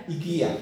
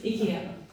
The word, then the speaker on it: IKEA: How do you say IKEA? – IKEA. – What about IKEA? I don't even know how to say it. – Properly. IKEA.